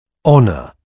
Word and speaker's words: honor: Je ne prononce pas le H. Mot à apprendre par cœur. – Honor.